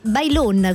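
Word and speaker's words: Baylón: Allora, buongiorno, il santo di oggi è San Pasquale Bailon, abbiamo visto spagnolo. Ma perché mi cambi? Bailon con la O chiusa.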